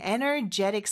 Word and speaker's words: energetic: Well, the word energy is E-N-E-R-G-Y, but we're going to change the Y to E-T-I-C. – 맞아요. 이 경우도 역시 energy라는 단어를 알면은 또 파생돼서 기억할 수 있는데, 근데 조금 다른 거는 발음이 강세가 달라져요. energy, 이름절인데, energetic, 삼음절의 강세가 있다는 것도 기억해 주세요.